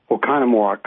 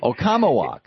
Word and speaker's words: Oconomowoc: Oconomowoc. – Thank you. – Really, every fools everybody, but it's really pretty easy. – Oconomowoc.